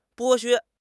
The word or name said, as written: 剥削